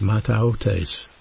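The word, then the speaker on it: mataotes (ματαιότης): That word vanity in the original language is pronounced mataotes, and it actually means an emptiness.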